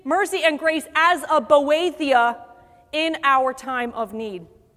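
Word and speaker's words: Boethia (βοήθεια): This word for help is the word, and I'm going to say it the way they said to pronounce it, Boethia.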